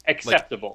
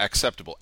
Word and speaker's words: acceptable: It isn't pronounced like kss. – Right. – Give me an example of when that is. – That is pronounced like a KS? – Acceptable. – Like, yeah, acceptable.